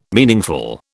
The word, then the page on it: meaningful: British English